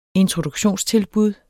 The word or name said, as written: introduktionstilbud